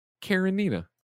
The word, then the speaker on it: Karenina: Nanobiologist writes in to correct my terrible pronunciation. The Tolstoy novel I was talking about is Anna Karenina. Anna Karenina.